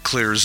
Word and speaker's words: Xlear: Also, you can find all of Clear's xylitol-containing products, that's the Clear nasal and dental products, because they've got your bases covered. Oh, by the way, Clear as in clear is spelled X-L-E-A-R.